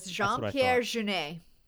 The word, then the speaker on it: Jean-Pierre Jeunet: It was Jean-Pierre Jeunet.